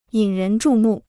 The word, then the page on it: 引人注目: yǐn rén zhù mù